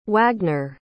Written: wagner

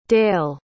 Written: dayle

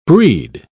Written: brede

